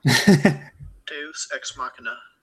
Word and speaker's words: Deus Ex Machina: Deus Ex Machina.